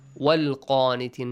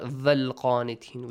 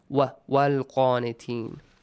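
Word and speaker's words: والقانتين: Sometimes wow is also important. Walqanitin, not valqanitin. We don't have the. Walqanitin.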